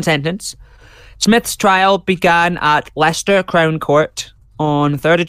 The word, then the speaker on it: Leicester: I must say, it's Leicester. That's what I wanted to say, because I know the Americans, if they Google this case, Leicester, Leicester.